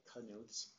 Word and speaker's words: Knuth: What about the N in there? – The K is hard. – President Lucas, managers, I went to school with Knuths. That's spelled the same way, so that's how I've been pronouncing it, as Knuth.